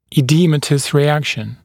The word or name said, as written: edematous reaction